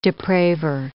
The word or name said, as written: depraver